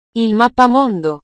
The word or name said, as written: il mappamondo